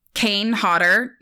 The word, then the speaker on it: Kane Hodder: was the Kane Hodder.